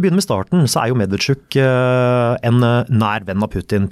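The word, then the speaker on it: Medvedchuk (Медведчук): Medvedchuk, ja.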